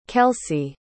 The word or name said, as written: kelsie